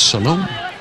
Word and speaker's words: Salome: Looks like Solomon. But if you go to the website and you click on the ear, each player pronounces the proper pronunciation of their name. It's Salome. For a piece.